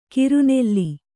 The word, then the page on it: ಕಿರುನೆಲ್ಲಿ: kiru nelli